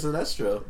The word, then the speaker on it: Sinestro: he killed it when he plays Sinestro. Thank you for saying it right.